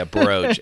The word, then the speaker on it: brooch: Yeah, brooch. Emphasis on the bro.